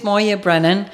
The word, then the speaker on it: Moya Brennan: Yeah, it is Moya Brennan. The reason that I've had to change my Gaelic name is... – Ja, man spricht meinen Namen wirklich Moya Brennan aus. Ich habe die Schreibweise zu Moya, M-O-Y-A, geändert, weil alle meinen gälischen Namen falsch aussprachen, erzählt Moya.